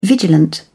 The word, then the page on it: vigilant: British English